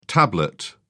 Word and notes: tablet: British English

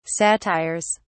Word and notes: satires: British English

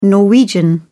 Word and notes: Norwegian: British English

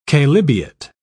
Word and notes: chalybeate: British English

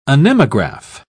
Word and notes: anemograph: British English

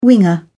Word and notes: winger: British English

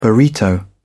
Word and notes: burrito: British English

